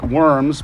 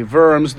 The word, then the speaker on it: Worms: In the city of Worms, spelled like worms, but you say Worms,